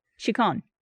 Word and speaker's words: Chicon: How do you pronounce C-H-I-C-O-N? – Oh, Chicon. – Chicon. – Here, it says Chicon. – Or Chicon Street. – Chicon. Mm-hmm. – It says Chicken.